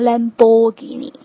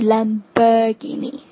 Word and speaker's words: Lamborghini: 下面一个多么 fancy 的牌子哈，Lamborghini，千万不要把它发成 Lamborghini，正确的发音是 Lamborghini。